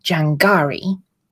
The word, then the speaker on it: Jangari: The local population of Jangari, yes, the N is silent,